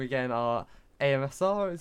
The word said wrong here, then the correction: ASMR: is it? – ASMR. – ASMR, sorry. AMSR. I pronounce that wrong every time.